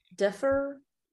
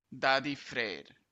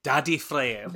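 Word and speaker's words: Daði Freyr: Differ. – This is a YouTube how to pronounce it that I just looked up, and it is Daddy Frey. – Daddy Frey.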